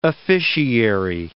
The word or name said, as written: officiary